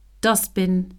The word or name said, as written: dustbin